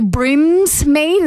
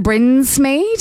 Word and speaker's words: Brinsmead: Some people like to say Brimsmead instead of Brinsmead.